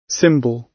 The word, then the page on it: symbol: British English